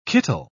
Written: kittle

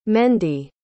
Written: mendie

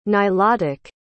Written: nilotic

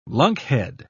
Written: lunkhead

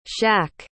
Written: shaq

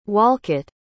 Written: Wolcott

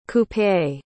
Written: coupé

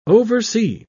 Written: oversee

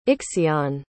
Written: Ixion

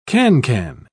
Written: cancan

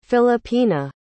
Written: filipina

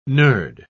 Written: nerd

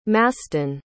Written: maston

